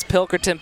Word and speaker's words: Pilkerton: Sneaks it by Williams, and Pinkerton has got a hard left-handed serve coming down on that right side. – For some reason, I like to butcher her name. It's Pilkerton, Pilkerton, Pilkerton.